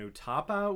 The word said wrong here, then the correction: Utapau: Utapau, you know, the planet with the sinkholes where Obi-Wan faced down and beat General Grievous once and for all.